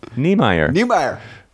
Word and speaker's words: Niemeyer: Niemeyer. – I have to think phonetically. It's like knee, like part of the leg.